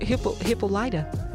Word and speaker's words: Hippolyta: Hippolyta? – Yes. You know I was saying it wrong.